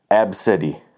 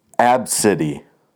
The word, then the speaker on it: Abcde: Her daughter's name is, let me spell it first, A B C D E, and it's pronounced Absidy. Absidy.